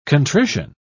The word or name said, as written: contrition